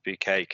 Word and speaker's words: Bucket: Early 90s British sitcom about a housewife called Hyacinth Bucket, which she pronounces Hyacinth Bouquet because she's always about trying to put on the airs and graces.